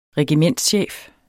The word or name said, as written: regimentschef